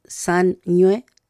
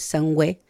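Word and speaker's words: sañwe: Ahí también, sanñue, que es como suena, pero se escribe con la letra ñ y la W, que ese sonido, ¿cierto?, que suena como una doble N, pero que no es una doble N. Entonces, no es sangüe, sería sanñue.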